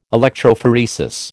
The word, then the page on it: electrophoresis: American English